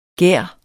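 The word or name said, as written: Gær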